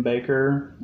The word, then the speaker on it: Bakker: Uh, this biopic look at Tammy Faye and her husband Jim Bakker uh, being televangelists.